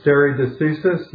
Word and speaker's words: stare decisis: Yeah, I think the technical term for that, and I may not be pronouncing it right, is stare decisis.